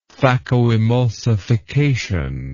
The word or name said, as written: phacoemulsification